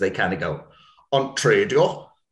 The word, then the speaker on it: TRADIO: And I'm guessing TRADIO, am I saying that right about the podcast? – Yes. Yeah.